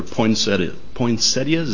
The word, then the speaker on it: poinsettias: They're poinsettias. Is that how you say it? Yeah.